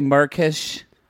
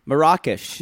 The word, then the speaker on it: Marrakesh: Kind of like she says, what does she say, Murkish? – Marrakesh. – Marrakesh. – It's not Morocco. It's Marrakesh.